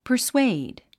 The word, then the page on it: persuade: pə r swéid; パァスウェイド